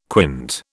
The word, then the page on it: quint: British English